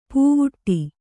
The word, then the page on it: ಪೂವುಟ್ಟಿ: pūvuṭṭi